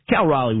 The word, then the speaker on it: Raleigh: But you said it right, which is Cal Raleigh.